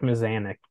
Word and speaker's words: Mazanek: Is it Milanchek? I said that right? – Marek Mazanek. Or Mazanek.